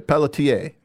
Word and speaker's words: Pelletier: No R? – Well, you don't pronounce it. – Okay, Pelletier.